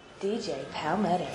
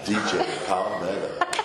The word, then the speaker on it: DJ Palmetto: You've got to say it right. DJ Palmetto. – DJ Palmetto.